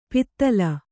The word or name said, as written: ಪಿತ್ತಲ